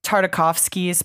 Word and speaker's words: Tartakovsky: um, the Genndy, yeah, uh, Tartakovsky's Primal.